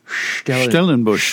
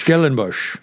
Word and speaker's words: Stellenbosch: How do you pronounce that? He got his PhD from the University of Stellenbosch. – Stellenbosch.